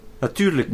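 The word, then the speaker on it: natürlich: Naturalisch. How do you say naturalisch? – Naturlich.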